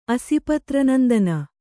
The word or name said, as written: ಅಸಿಪತ್ರನಂದನ